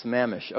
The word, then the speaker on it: Sammamish: Sammamish.